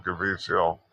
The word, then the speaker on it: Caviezel: I think that's what Jim Caviezel, is that how you pronounce his name? – Yeah.